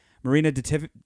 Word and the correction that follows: Marina de Tavira: Marina De Tavira.